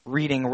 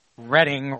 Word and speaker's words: Reading: From Connecticut, we say Lancaster, but I had to learn over the years that it's Lancaster, just like it's not Reading Railroad. It's Reading Railroad. But all my life, I always said in Monopoly, I got Reading Railroad.